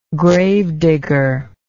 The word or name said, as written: gravedigger